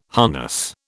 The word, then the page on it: harness: British English